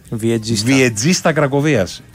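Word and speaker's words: Wieczysta Kraków: πώς προφέρουμε τους γηπεδούχους, παίζει και ο Κουλούρης. Ναι, λογικά. Η προφορά της ομάδας, επειδή την έχω πει και στο στοίχημα πολλές φορές στα Σουκού, είναι Βιετζίστα Κρακοβίας.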